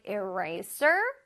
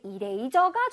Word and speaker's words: eraser: Is that my eraser? 나왔어요. 자, eraser 할 때 eraser라고 우리 친구들이 할 텐데 eraser가 절대 아니라 eraser. 자, eraser하고 sir를 아주 강렬하게 발음을 해줍니다.